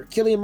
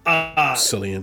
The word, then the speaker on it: Cillian: Cillian Murphy, or Killian Murphy, whichever way you say his name. – Cillian.